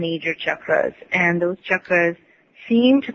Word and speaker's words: chakras: Yes, and chakras, which is pronounced with a ch and not an sh, some people say that there's 108.